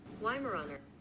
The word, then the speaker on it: Weimaraner: Weimaraner.